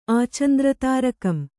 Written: ಆಚಂದ್ರತಾರಕಂ

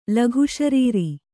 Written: ಲಘುಶರೀರಿ

